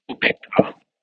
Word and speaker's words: upekkha: Upekkha. UPEKKHA long A upekkha dash SATI sati dash, because this is one word, supposed to be one word, but so I separate PA long A RI SUDDHI parisuddhi.